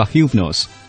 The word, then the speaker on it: Hypnos: 对，呃，提到催眠，可以给大家解释，呃，提到这样一个人物，就我们小时候包括在看《圣斗士星矢》啊这些动漫当中，有一个人物叫休普诺斯，他的英文发音叫 Hypnos。